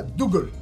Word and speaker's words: Dougal: Esse, não, escocês, desculpa, falei bobagem, escocês, mas ele se chama Du, se escreve Dougal. E na série, toda vez que falava o nome dele era Dougal. Dougal.